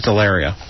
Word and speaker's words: DeLaria: Oh, it was Delaria. Rhymes with Hilaria.